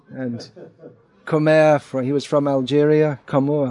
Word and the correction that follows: Camus: Camus, Albert.